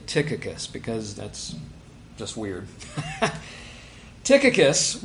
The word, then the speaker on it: Tychicus: Now, I almost always pronounce the first name as Tychicus because it's what you do as an American. The Greek is Tychicus.